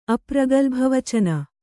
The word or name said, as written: ಅಪ್ರಗಲ್ಭವಚನ